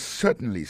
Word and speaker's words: certainly: Yes, certainly, sir. Yes, certainly, sir. Certainly. Yes, certainly, sir. Alors, accent tombe sou premier syllabe la. Yes, certainly, sir. Oui, certainement, monsieur. Alors, nan mou sir, qui écrit S-I-R, ça veut dire monsieur.